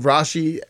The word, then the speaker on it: Rashi: So people are quoting Rashi. That's the Ashkenazi pronunciation for him, by the way. They're quoting Rashi,